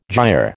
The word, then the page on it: gyre: American English